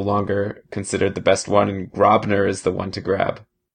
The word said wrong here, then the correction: Grubauer: Actually, I think it's pronounced Grubauer, but of course, I have my own troubles with that sort of thing.